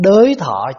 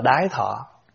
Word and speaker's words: đới: Thiện tai giải thoát phục, vô thượng phước điền y, ngã kim đảnh đới thọ, chứ không đọc là đái thọ. Mặc dù cái chữ gốc nó là vậy, nhưng khi chúng ta đọc qua tiếng âm Hán Việt của mình thì chúng ta đọc là đới.